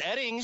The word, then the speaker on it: Oetting: Mark Oetting. – Hold on. It's pronounced Edding. – But it's spelled O-E-T-T-I-N-G. – Yes, and O-E-T-T-I-N-G. It's pronounced Edding. Take two.